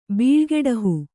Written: ಬೀಳ್ಗೆಡಹು

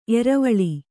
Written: ಏರ್ವಲ

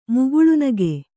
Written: ಮುಗುಳ್ನಗು

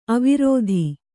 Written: ಅವಿರೋಧಿ

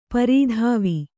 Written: ಪರಿದೇವ

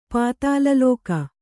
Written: ಪಾತಾಲಲೋಕ

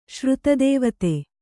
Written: ಶ್ರುತದೇವತೆ

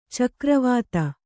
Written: ಚಕ್ರೀವತ್ತು

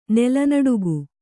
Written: ನೆಲನಡುಗು

